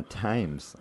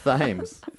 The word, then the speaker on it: Thames: I'm going to go drown myself in the Thames. – I fucked up saying it wrong. – That's what I'm... Oh. – Thames. God damn it.